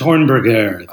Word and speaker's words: Hornberger: Hornberger on NBC's 30 Rock. Did I say that right? Hornberger? – It's Hornberger. – Hornberger. Yeah, it's the French.